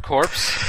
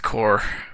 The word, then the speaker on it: Corps: Hard corpse. – Yeah, but it's just pronounced core. – Oh. – like Marine Corps.